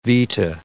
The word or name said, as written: vita